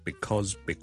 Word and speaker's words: because: Because 呢个字个发音，好多时候咧，我哋系唔会读成好完整嘅，我哋正确啦，beause，我哋系 because 系 b 啦，第一个 vowel 系 e 啦，第二个 vowel 系 o，跟住后面系一个 z 音，because，但系咧喺口语嘅时候咧，尤其是当呢个字唔系咁紧要嘅时候咧，我哋可能会读得好快好快，大家都可能会见过 coz 呢个简写，ok。